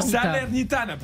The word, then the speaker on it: Salernitana: lui qui est à la Sadler. Sadler. Ah, alors. pourtant, Salernitana. Pourtant, c'est italien, c'est facile à prononcer.